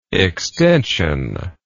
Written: extensionless

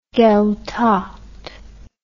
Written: gaedhealtacht

